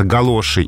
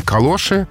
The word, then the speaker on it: галоша: В словарях дается «галоши» и «калоши» как абсолютно равноправные варианты, произносительные, соответственно, орфографические, как «матрас» и «матрац».